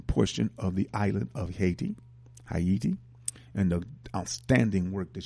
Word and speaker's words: Haiti: And Sanjay, S-O-N-J-E dash Haiti, A-Y-I-T-I .org. That is, of course, the correct pronunciation of Haiti, which is Haiti. You know, so just want to make sure you knew that.